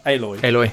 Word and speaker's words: Aloy: Aloy.